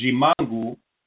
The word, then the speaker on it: Ximango: And another weak mate that's also very delicious is Jimangu, and that's spelled Ximango, Jimangu.